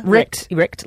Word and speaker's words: Richt: How do you feel about Richt, is that how you pronounce it? – Yep. Richt, like the Richter scale.